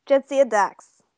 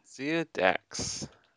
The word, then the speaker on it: Jadzia Dax: Zad Azdaj. – Jadzia Dax. – Perfect. – Mm. – Jadzia Dax.